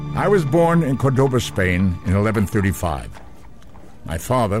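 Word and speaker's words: Córdoba: No disrespect to Mr. Spock, but other sources say he was born in 1138, and it's pronounced Cordoba.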